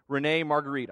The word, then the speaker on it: René Magritte: There's a painting by a guy by the name of René Magritte.